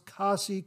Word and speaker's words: Casi: By the way, you pronounce Cassie correctly in Cassi Perfecto.